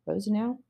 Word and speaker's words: Rosenau: Rosenau, I think is how you pronounce his name, Rosenau, R-O-S-E-N-A-U.